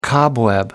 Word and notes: cobweb: American English

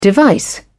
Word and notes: device: British English

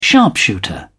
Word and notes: sharpshooter: British English

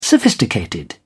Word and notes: sophisticated: British English

